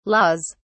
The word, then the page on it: laws: British English